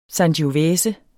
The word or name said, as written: sangiovese